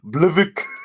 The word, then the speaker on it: BLVCK: Blvk. – Blvk. You spell that B-L-V-C-K.